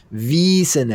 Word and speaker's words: Wiesen: Theron Senvisen, pronounced Wiesen at the end. Tip for German. When there is an IE or EI, you pronounce the second letter.